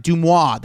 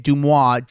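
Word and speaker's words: DeuxMoi: Make sure you follow her at DUXMOI, Dumois. That's how you say it, Dumois.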